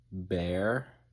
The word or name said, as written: bear